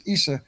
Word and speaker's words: Isa: They pronounce it Isa.